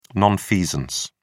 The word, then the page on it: nonfeasance: British English